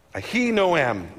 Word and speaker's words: Ahinoam: Ahinoam is what I'm going to say. That's how you say her name.